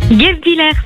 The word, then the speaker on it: Guebwiller: On va accueillir a 38 ans, elle est du côté de Guebwiller, c'est dans le 68, j'espère bien le prononcer. Salut. – Salut la team, comment vous allez? – Salut. Guebwiller, c'est ça qu'on dit? – Guebwiller, c'est ça.